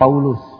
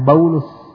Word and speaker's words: Paulus: Paulus ya, yang kita kenal itu pemimpin rohani agama Nasrani, Paulus. Jadi di sini cara membacanya Paulus, karena dalam bahasa Arab nggak ada p, diganti dengan ba, Paulus.